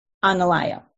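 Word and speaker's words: Anālayo: Tewari quoted the Buddhist monk, let's see, how do you pronounce this? I don't know if it's Anilayo. Is that what it is? Anilayo, yeah.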